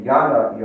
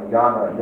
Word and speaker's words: jnana: But Hinduism has four yogas, and they are a jnana yoga, usually they spell it J-N-A-N-A, J-N-A-N-A, which is yoga of knowledge, that's a thinking yoga.